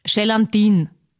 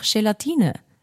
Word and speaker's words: Gelatine: die sagen zum Beispiel Gelatine. Und ich weiß schon, ich will jetzt auch nicht super gescheit oder obergescheit da daherreden, aber ist halt einfach falsch. Und sagt's mir, wenn ich irgendwas falsch ausspreche, aber man kann auch sagen, es ist einfach Gelatine.